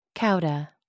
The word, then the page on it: cauda: American English